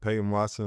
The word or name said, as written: Peyton Watson